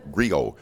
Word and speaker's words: griot: And the book is called Race, Religion, and Reflection, and I am referred to in my poetry as the Octo Griot. The T is silent in the word, so you can't find it in a dictionary.